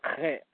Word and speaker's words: Chet: You know, you got to use your throat when you pronounce it. It's more like Chet, Chet.